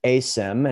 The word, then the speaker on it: Asym: And am I pronouncing that right? Asym? – Yeah. Yeah. So the company name is Asym, and it's short for asymmetric, and basically discusses kind of the disproportionate returns to the perceived risk or the incurred risk.